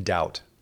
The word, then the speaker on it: doubt: Please, what is your take on the silent B? What is that all about in doubt? – I don't know.